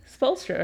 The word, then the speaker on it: Spoelstra: Spoelstra, right? – Yes.